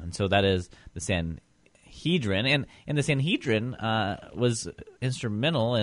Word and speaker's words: Sanhedrin: The buzzword I have for you today, I hear it pronounced a couple different ways. I always call it the Sanhedrin. Some people call it the Sanhedrin.